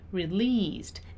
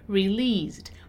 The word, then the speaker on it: released: Okay, there is a long E sound right in the middle of the word. So released. And notice also the pronunciation of the ED in the end, released. We don't really pronounce the letter E.